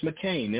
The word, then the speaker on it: McCain: And in case you don't know how to spell my name, it's T-I-E-M-E-Y-E-R, T. Meyer. The last name is McCain, M-C-C-A-I-N. And Tasila, her name is spelled T-O-S-I-E-L-A, Tasila.